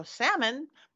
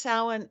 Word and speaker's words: Samhain: Yes, Samhain, and it is spelled S-A-M-H-A-I-N. So a lot of people go salmon, but it's not. The M is silent. It's Samhain.